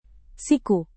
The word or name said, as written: siku